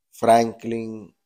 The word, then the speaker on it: Franklyn: Y por aquí su servidor, Franklin Cruz.